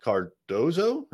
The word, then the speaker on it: Cardozo: Cardozo.